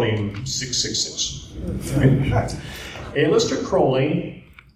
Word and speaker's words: Crowley: And he glared at me across the table and he said, "It's Aleister Crowley. His name rhymes with holy."